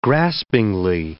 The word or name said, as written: graspingly